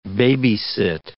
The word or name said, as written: baby-sit